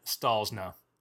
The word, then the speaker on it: Stollznow: And our very, very special guest, Dr. Karen Stollznow.